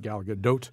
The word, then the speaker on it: Gal Gadot: I looked it up. That's how you say it. Gal Gadot as Wonder Woman.